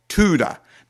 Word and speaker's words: Todah: The third word, the holy praise word of Tuda. Now, it's actually spelled T-O-D-A-H in English, but it's pronounced in Hebrew, Tuda.